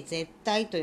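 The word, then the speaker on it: 舌苔: でそれをするとあの舌についてるですね白っぽいものこれをあの絶対下の苔と書いて絶対と読むんですけれどもこれが取れるわけですね。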